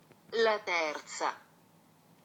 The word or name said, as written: Laterza